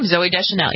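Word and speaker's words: Zooey Deschanel: One of them was Zoe Deschanel. Is that how you pronounce her name? – That's how you pronounce her name, Zoe Deschanel, yes.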